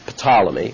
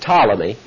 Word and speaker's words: Ptolemy: Ptolemy, that's pronounced T-O-L, not Ptolemy, but Ptolemy.